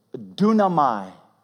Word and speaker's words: dunamai: The actual word is dunamai. Just try it at the lunch table tomorrow. I've been practicing all day to say it right. That's really how you say it in the Greek.